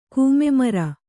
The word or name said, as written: ಕೂಮೆಮರ